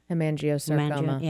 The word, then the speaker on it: Hemangiosarcoma: Hemangiosarcoma.